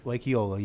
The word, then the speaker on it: Lake Eola: Lake Eola, you got it right.